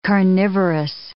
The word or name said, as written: carnivorous